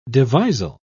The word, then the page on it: devisal: British English